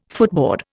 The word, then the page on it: footboard: Indian